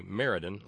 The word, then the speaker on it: Meriden: Okay, Meriden, like the horse. And the E is a long E.